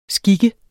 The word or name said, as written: skik